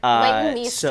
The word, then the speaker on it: Leighton Meester: And they, you know, they punch up the virgin singer, Leighton Meester, or whatever her name is. I'm going to butcher her name, too. Sorry, Leighton, whatever. – Leighton Meester.